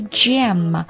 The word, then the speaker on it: jamb: 注意一下这个 jamb, 它这个 b 是不发音的，它是表示这个窗子啊，或者说这个门的侧柱。 Jams 有点像果酱那个单词啊，读音是一模一样的。